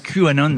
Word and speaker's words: QAnon: Canon, je ne sais pas si je le prononce bien, le QAnon. QAnon, voilà, à cette mouvance complotiste, c'est ça, dont il se serait inspiré.